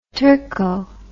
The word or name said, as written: turco